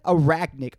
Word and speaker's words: arachnid: I am an anagric. How do you say that? Arachnid, anagric. That sounds like a very, like, Arabian black racial slur.